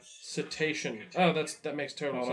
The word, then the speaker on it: cetacean: So you know the word crustacean? – Sure. – It's in the same, so it's cecetin or something? Like, it's not. – Cetacean? No. – You don't pronounce the A. It's like, you go right through it.